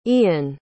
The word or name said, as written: ean